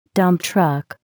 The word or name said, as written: dump truck